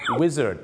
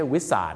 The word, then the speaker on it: Wizard: ออกเสียงว่า Wizard นะครับไม่ใช่ Wizard